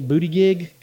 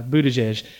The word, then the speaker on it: Buttigieg: Can I say a booty gig? Because it looks like booty gig. Nah, Buttigieg.